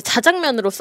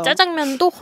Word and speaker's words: 짜장면: 앵커들이나 아나운서들이 뭐 얘기하다가 짜장면 얘기가 나오면 갑자기 자장면 이렇게 발음할 때 되게 네. 인위적이라는 생각도 약간 효과 들고. 있잖아요. 효과. – 맞아요. 쓰는 사람들의 그 또 쓰임새에 맞춰서 해야지 억지로 우리가 자장면 이러기는 또 어려웠거든요.